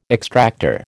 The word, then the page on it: extractor: American English